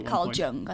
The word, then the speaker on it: Carl Jung: Carl Jung, I think it's pronounced. – Yes.